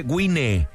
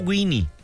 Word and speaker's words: Wine: Se escribe Guine, no Guini, Guine, Baja Guine, Baja Wine.